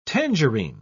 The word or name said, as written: tangerine